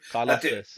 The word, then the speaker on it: Karlaftis: Karlaftis.